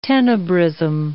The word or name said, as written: tenebrism